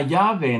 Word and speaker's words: Yahweh: YHWH，它的发音可能就很像什么，Yahweh，Yahweh。 OK，他们的 W 比较像 V 的音，Yahweh。那 Yahweh 呢，你这样子发出来，那就是你没有母音的话发不出来，所以英文翻译的时候就把 Yahweh 翻成什么？